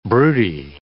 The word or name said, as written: broody